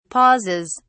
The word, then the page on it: pauses: British English